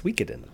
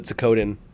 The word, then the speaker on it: Suikoden: It's pronounced Suikoden. – Sokidan. – I always called it Sokodin when I was growing up.